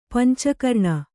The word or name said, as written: ಪಂಚಕರ್ಣ